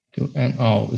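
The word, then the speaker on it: Kill 'Em All: E o primeiro disco saiu em 83, que certamente eu vou errar a pronúncia, mas é Kill and All, é isso? Kill and All, isso aí.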